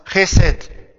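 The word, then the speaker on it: chesed (חסד): Now, the actual Hebrew word here is pronounced chesed.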